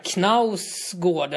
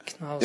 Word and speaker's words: Knausgård: Alltså det är liksom så här, Knausgård, eller vad heter han, Knausgård eller Knausgård, norsken som har skrivit Min kamp. – Gård skulle man väl säga kanske. Knausgård.